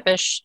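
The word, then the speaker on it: Țepeș: I'm saying that right? – Tepish, yes. Tepish.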